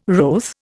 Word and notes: wroth: Indian

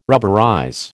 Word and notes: rubberize: American English

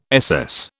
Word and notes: SS: British English

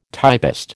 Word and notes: typist: American English

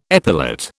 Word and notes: epilate: British English

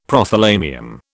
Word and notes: Prothalamium: British English

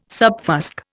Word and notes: subfusc: Indian

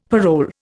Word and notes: parole: Indian

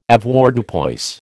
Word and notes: Avoirdupois: American English

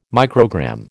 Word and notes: microgram: American English

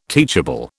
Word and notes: teachable: British English

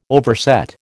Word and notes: overset: American English

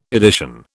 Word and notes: edition: British English

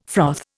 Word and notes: froth: Indian

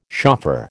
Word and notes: shofar: American English

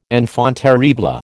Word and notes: enfant terrible: American English

